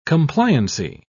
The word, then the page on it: compliancy: British English